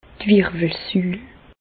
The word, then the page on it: d' Wirwelsül: Bas Rhin; Reichshoffen